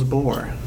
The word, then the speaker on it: Bohr: Albert Einstein, Isaac Newton, Niels Bohr.